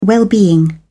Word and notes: well-being: British English

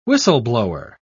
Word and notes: whistleblower: British English

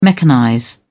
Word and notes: mechanize: British English

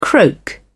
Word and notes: croak: British English

